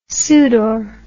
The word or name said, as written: sudor